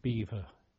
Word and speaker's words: Belvoir: The end of the week there, whilst there was an air raid going on, a signal came in saying I was appointed to HMS Beaver, which is spelt Belvoir, which was a Hunt-class destroyer, and I found out that she was operating in Scapa Flow.